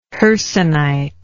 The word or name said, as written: hercynite